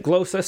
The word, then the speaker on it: glossa: But the prompt for this week was to write a glossa or glossa. I still don't know how to pronounce it. But in her poem, I realized that Diane Seuss pronounced it glossa. So we'll go with glossa.